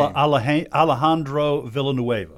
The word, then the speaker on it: Alejandro Villanueva: Alejandro Villanueva.